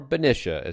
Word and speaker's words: Benicia: Benicia. Or Benicia, as Harold has always pronounced it.